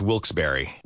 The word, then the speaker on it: Wilkes-Barre: Now, I had heard that it was supposed to be pronounced Wilkes-Barre.